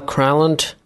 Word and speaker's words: Granlund: Mikhail Granlund, center, Helsinki. – Sounds like he's got it down to like one syllable.